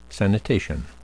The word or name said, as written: sanitation